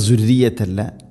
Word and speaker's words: ذريتي: ഇനി ذريتي റ ഇന ശദ് ഉണ്ട് എന്നാലും ദുർറിയത്തി പറയരുത്, തർക്കീഖ് ചെയ്യണം, നേർപ്പിക്കണം. ദുർറിയത്തി ദാലിൻ്റെ ഉച്ചാരണം ശ്രദ്ധിക്കുക, ദുർറിയത്തല്ല, ദുർറിയത്തി.